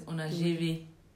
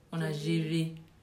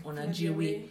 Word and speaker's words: Onajewe: The real pronunciation is Onajewe. Onajewe. Like that VWE at the end. then the pronunciation I permit people to pronounce is Onajiwe.